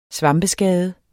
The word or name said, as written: svampeskade